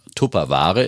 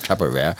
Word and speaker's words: Tupperware: Haben, also sprechen wir von Tupperware im Deutschen so ausgesprochen oder Tupperware natürlich. – Tupperware im Englischen.